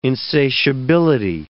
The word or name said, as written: insatiability